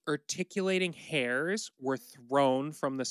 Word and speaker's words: urticating: It's spelled U-R-T-I-C-A-T-I-N-G. Articulating.